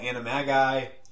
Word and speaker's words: Animagi: A lot of stuff with Pettigrew, all the stuff with the Marauder's Map and them being all Animagi. Is that how you say it? Because I know Animagus, that's the singular. Do you have to do a weird thing for the plural? – Sounds right.